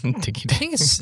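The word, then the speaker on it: Digiday: Is it Diggy Day or Digi Day? – Diggy Day.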